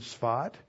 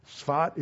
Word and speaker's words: Safed: There were some Jews in Sfat. There were some Jews in Jerusalem. Sfat is spelled S-A-F-E-D. That's for the transcriber because they'll never figure it out otherwise. S-A-F-E-D, and that's sort of that is a town that has a history going back all the way to the time of Jesus.